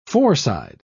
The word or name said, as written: foreside